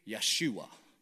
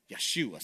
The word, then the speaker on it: Yeshua: The way Jesus would be pronounced in Hebrew would be Yeshua. Yeshua. Sounds like Joshua for us, right?